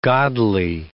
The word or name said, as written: godly